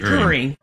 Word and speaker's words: Turing: It's a win, not an if, that AI passes the, what is it, a Turing test? Am I saying that right? – Turing.